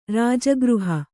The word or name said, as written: ರಜೋಗ್ರಹ